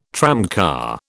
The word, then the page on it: tramcar: British English